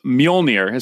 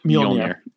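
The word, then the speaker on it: Mjölnir: So Mjolnir is how you say that, the name of it. – Mjolnir.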